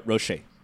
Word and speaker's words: Roché: Yeah, no, it is fascinating to, you know, beyond him coming back to Rochefort, is that how you say his name? – Rocher. – Oh, Rocher, sorry.